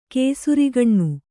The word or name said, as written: ಕೇಸುರಿಗಣ್ಣು